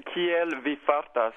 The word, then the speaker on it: kiel vi fartas: And I think I was supposed to say something like, kiel vi fartas? Is that how are you? – Yeah, kiel vi fartas.